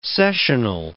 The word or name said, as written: sessional